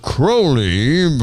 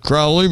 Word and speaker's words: Crowley: He was a 1940s practitioner of black magic known as Aleister Crowley, but Ozzy pronounced it Crowley, but it's okay.